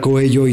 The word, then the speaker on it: Coello: ¿Por qué se pronuncia Coello y no Coelho, güey? – No, pendejo, porque la doble L siempre suena como Y en castellano. No sé si lo tengas claro. En castellano.